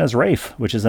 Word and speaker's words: Ralph: Ralph Fiennes, the great actor Ralph Fiennes. You enjoyed him in Quiz Show and many other movies. His name is Welsh. Pronounced Rafe, R-A-F-E, but spelled Ralph, R-A-L-P-H.